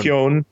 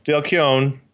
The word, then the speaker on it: Keown: Keown. – Dale Keown, Mark Farmer on inks with Mark Deering and Walden Wong and Scott Hahn, a lot of anchors.